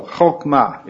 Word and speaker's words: chokmah: This is ch, okay? This is the guttural one. The cheth, okay, is the word letter. Okay, so chokmah is how you say that, okay? Well, that's how I say that. A real Hebrew speaker might say it slightly differently.